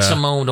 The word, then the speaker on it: Simone: And, Matt Simone, don't forget the E. – Yeah, with a long E.